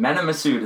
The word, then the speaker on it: Mena Massoud: Mena Massoud is the guy who plays Aladdin.